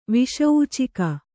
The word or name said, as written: ವಿಷೂಚಿಕೆ